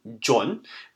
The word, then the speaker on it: John: That means examples like sandwich, sandoichi, or another example could be my name, John, would be how we put them in katakana.